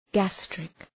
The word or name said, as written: gastric